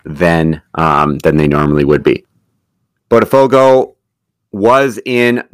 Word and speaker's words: Botafogo: Navarro currently playing for, James, am I saying it right, Botafogo? – Botafogo, yeah, Botafogo.